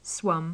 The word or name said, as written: swum